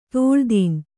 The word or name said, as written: ತೋಳ್ದೀನ್